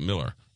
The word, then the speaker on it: Mueller: It looks like Mueller, but you pronounce it Miller. M-U-E-L-L-E-R.